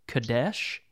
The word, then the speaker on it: Kadesh: Is it Kadesh? – Yeah, no, that's right.